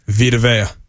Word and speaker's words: Vita Vea: How do you say Vita Vea? – Vita Vea. – Okay, you did it right.